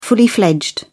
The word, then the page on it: fully fledged: British English